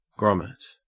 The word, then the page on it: grommet: Australian English